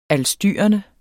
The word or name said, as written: ALSTYRENDE